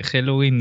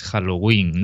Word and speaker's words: Halloween: O sea, no se dice Halloween ni, ni Halloween, con, la, con el acento en la sino que es, es, Halloween, ¿no? Como si tuviese tilde en la, en la I que no tiene.